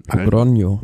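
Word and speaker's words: Logroño: Zum, zum Logronio. Logronio. Also ist das Nyon, Nyon, wenn das das schlecht Enye. Enye, okay.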